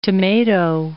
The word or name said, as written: tomato